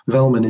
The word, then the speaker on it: Velleman: David Velman on this one. – How do you spell that? – Velman is V-E-L-L-E-M-A-N.